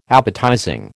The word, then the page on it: appetizing: American English